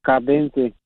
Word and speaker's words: Cadence: A Cadence. – Cadente, eh, Cadente así se llama, eh, Cadent, ¿cómo se dice? Este, Cadente, ¿es banco o Credit Union? Cadence. Cadence, ah, Cadence, ok, Cadence, ok.